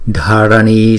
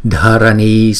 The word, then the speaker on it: dharani: Chữ D và chữ H ghép lại thì chúng ta đọc là dha, dha, dharani. Chữ N chúng ta phải cong lưỡi lại ha và búng ra n, n, dharani, dharani, Dharani Swaha, Dharani Swaha.